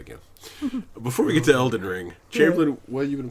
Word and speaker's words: Elden Ring: Before we get to Elden Ring. – You're saying it wrong. They never say Elden Ring. It's Elden Ring.